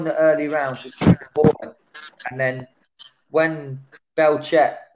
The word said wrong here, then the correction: Burchelt: have I got his name right? – Burchelt. – When Burchelt had his, you know, he had his stage where he was coming on strong, front footing.